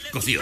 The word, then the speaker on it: Cosío: No, Cosio, no. Cosio. Con acento en la C. No, no, no, no, no es cierto. Cosío, ¿no? Productor, es Cosío.